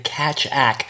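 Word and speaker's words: Keccak: And by the way, I now learned the pronunciation of the algorithm behind this new standard is CACHACK. So this is at least how I've been told I'm supposed to pronounce it.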